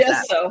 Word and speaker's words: gesso: I don't know how to pronounce gesso. – That. It's gesso. It's a G that's pronounced J.